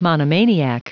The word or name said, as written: monomaniac